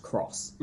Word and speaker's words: cross: It is something where the case, it's an X. And so you might look at it and read it as being an X, but it's actually meant to be pronounced cross.